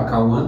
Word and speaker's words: Kauhan: Ah, é, para quem estiver assistindo, o meu Instagram é Cauan T, procura lá, Cauan Teixeira. O meu nome se escreve K-A-U-H-A-N.